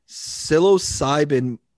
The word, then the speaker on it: psilocybin: psilocybin mycologist Paul Stamets.